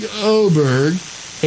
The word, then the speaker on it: Åberg: Even though it says Åberg, there's a little line above his name, and evidently that's called an accent aigu. – And evidently over there in Sweden, A is pronounced like an O.